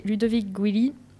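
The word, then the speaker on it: Ludovic Giuly: Et euh, pour la saison 2015, l'ancien footballeur international français Ludovic Guilly, euh, oui, c'est comme ça, ça se prononce, devient l'ambassadeur du foot golf en France.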